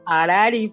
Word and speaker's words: Harari: Dinos cómo se pronuncia. – Harari, porque H es muda.